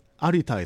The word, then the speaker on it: Arita: No, Arita is my name.